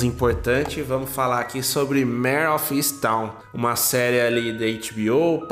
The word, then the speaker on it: Mare of Easttown: Chegou chegando uh, é, Mare of Easttown.